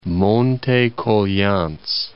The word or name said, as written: Coglians, Monte